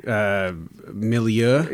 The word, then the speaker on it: milieu: Just an aesthetic milieu. – Yeah, yeah. – Milieu. If you've never heard that word pronounced.